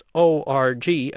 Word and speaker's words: org: Since its creation in the fall of 1984, people in the know have pronounced ORG as a single syllable org, the first syllable of organization.